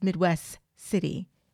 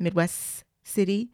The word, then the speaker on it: Midwest City: And I remembered he pronounced the final T in Midwest. Nobody says the T. It's Midwest City. Do you hear that? Midwest City. We continue holding that S to the next M sound.